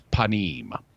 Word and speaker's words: panim: So the word for face in Hebrew is panim.